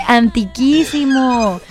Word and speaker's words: antiquísimo: Antiguísimo, dijo un buen amigo que le dije, no se dice antiguísimo, se dice antiquísimo.